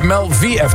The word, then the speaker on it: Mel VF: Dat is niet meer, maar toen dacht ik later was het Mel VF, maar het blijkt Mel VF te zijn. – Oh, de Engelse uitspraak.